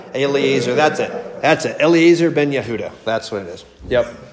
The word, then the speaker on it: Yehuda: Grant's gonna like this because it rhymes with Yoda, but the man who resurrected it was Eliezer ben Yehuda. – Yehuda, thank you. Ben Yehuda. – Sounds like Yoda.